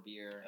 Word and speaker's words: Bier: Beer, B-I-E-R, German spelling. – So is B-I-E-R pronounced buyer or just beer? – Beer.